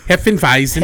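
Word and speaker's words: Hefeweizen: Heffenweizen.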